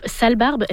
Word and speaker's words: Sale Barbe: Et en terminant, on a un nouvel album pour le groupe Sale Barbe. Est-ce que c'est bien comme ça qu'on prononce? – C'est bien comme ça qu'on prononce, vous avez bien fait.